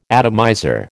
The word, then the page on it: atomizer: American English